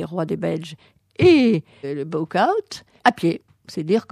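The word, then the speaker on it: Bouchout: C'est donc le château de Buchaut, ainsi que le prononcent les Belges, c'est-à-dire Bouchou en français.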